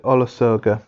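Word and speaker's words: Olusoga: I think I mentioned that previously, but I tried to start reading Black and British by David Olas. Sorry, let me get that right. I don't want to pronounce his name incorrectly. David Olasoga, the historian.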